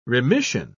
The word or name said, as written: remission